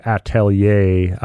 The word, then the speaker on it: atelier: Well, I appreciate you pronouncing it correctly. So atelier is basically, like, it's a French term for, like, a workshop.